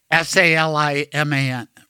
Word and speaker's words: Saliman: And but should it be pronounced just like Saliman? – Yes.